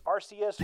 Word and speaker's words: Arceus: It's Arceus, it's Arceus, it's Arceus, it's Arceus.